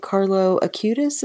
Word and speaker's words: Carlo Acutis: Well, and there are saints that aren't the big, big, great ones, but that, like, again, I'm struck by St. Carlo Acutis.